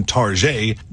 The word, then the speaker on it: Target: The progress Target has made toward turning its business around and recapturing its former discount chic vibe that helped inspire the alternate pronunciation Target will be closely watched today.